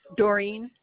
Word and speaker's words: Doreen: Doreen, D-O-R-E-E-N.